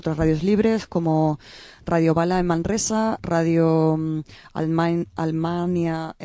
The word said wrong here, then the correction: Almaina: en Granada. – Almaina. – Almaina, ay, que siempre lo digo mal, que me perdonen.